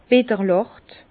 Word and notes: bitterlächt: Bas-Rhin; Herrlisheim

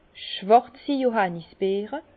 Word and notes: schwàrzi Johannisbeere: Bas Rhin; Herrlisheim

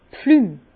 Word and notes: d' Pflümm: Bas Rhin; Herrlisheim

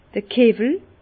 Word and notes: d'r Kewwel: Bas Rhin; Herrlisheim

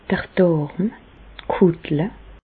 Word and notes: d'r Dàrm, d' Kùttle (pl): Bas-Rhin; Reichshoffen

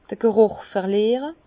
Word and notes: d'r G' rùch verliere: Bas-Rhin; Herrlisheim

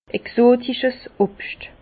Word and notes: exotisches Obscht: Bas Rhin; Herrlisheim

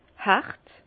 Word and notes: hert: Bas-Rhin; Herrlisheim

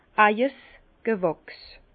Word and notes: Eijes Gewächs: Bas-Rhin; Herrlisheim

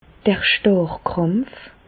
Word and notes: d'r Stàrrkràmpf: Bas Rhin; Reichshoffen

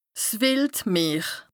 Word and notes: 's wìld Meer: Bas-Rhin; Strasbourg

